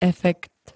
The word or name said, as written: efekt